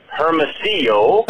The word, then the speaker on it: Hermosillo: I got to tell you, it's pronounced Hermosillo, whatever it is that you said.